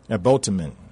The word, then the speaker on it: Ebhotemen: Ebhotemen.